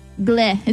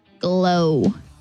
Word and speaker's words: Glo: How do you say this? G-L-O. Gleh. Is that how you pronounce it? Gleh fiber? Glow.